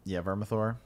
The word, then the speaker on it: Vermithor: Vermithor? – Yeah, Vermithor. – Yeah, we'll go with Vermithor.